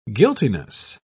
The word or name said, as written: guiltiness